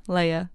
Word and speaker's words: Leia: We're not naming this baby Leia either. Leia. Sorry. Don't write a letter. I mispronounced it.